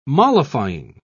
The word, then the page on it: mollifying: British English